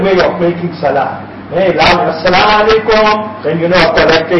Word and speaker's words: As-Salaamu Alaikum: It's not Salaam Alaikum, As-Salaamu Alaikum. Pronounce that Alif, that Hamza at the beginning.